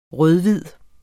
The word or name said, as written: rød-hvid